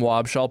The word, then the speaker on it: Wobschall: Um, I have a weird last name, Wobschall.